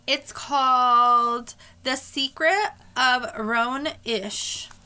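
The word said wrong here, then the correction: Roan Inish: Rowan Inish. It's O-R-O-R-O-A-N-I-N-I-S-H.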